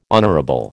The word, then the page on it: honourable: American English